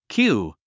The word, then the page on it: cue: kjúː; キュー